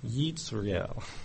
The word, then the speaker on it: Jezreel: The word Jezreel is pronounced something like Yitzriel, and it means God will scatter.